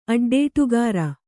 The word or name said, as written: ಅಡ್ಡೇಟುಗಾರ